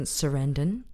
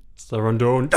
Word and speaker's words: Sarandon: You may have heard fantastic, riveting interview with director Laureen Scafaria and actress Susan Sarandon. – Sarandon.